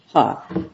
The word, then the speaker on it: He: Even if we disagree with the board's claim construction, could we conclude that error was harmless because it still would have been obvious to modify, is it he? – It's pronounced ha in China. – Ha. – I said he for a long time, but ha is correct.